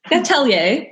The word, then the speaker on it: Atelier: Am I saying that right? Like, I mean, I'm French, but I feel weird. How do you guys pronounce it? – Love it. – Yeah. What do you say? What do you guys say? – Atelier.